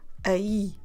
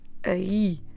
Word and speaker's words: aí: and we actually pronounce aí, aí, stressing the last bit.